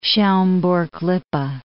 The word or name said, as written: Schaumburg-Lippe